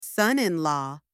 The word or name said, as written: son-in-law